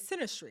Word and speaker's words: synastry: So I'd love to transit, funny pun, into our last section of this episode, which is synastry. I'm saying that correctly, mm-hmm. like, correctly, yeah. – right? Synastry? – Yeah.